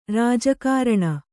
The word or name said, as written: ರಾಜಕಾರಣಿ